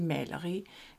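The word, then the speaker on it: maleri: Die Mälerie ist also die Malerei, Kunst. Denn in Dänemark werden die meisten A's eigentlich als Ä ausgesprochen. Aber dieses dänische Ä beim A ist ein anderes als das Ä. Denn das ist eher dann so wie ein E. Und die A's werden wie Ä ausgesprochen, aber das Ä wird wie ein E ausgesprochen.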